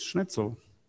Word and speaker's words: Schnitzel: I think the most popular German food is schnitzel.